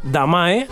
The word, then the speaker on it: Damae: igual si te recuerdo las palabras Fragma o Damae, que es como pronunciamos nosotros su nombre, igual te suena de algo.